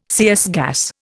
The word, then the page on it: CS gas: Indian